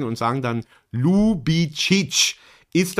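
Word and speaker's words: Ljubičić: Und dieses LJ ist eigentlich ein Buchstabe in unseren Sprachen und wird wie L ausgesprochen. – Okay. – Und deswegen heißt der Junge Ljubicic.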